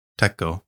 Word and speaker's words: Tekko: It's spelled T-E-K-K-O. Teko. – Is it Teko?